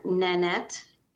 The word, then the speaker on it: Nanette: Nanette, N-A-N-E-T-T-E, Elzinga, E-L-Z as in zebra, I-N-G-A, okay.